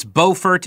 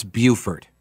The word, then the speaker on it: Beaufort: In North Carolina, it's Beaufort. In South Carolina, it's Beaufort. Okay. Always happy to help out our New York brothers. Thank you, Dan. I appreciate it. So Beaufort.